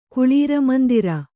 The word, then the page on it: ಕುಳೀರಮಂದಿರ: Kannada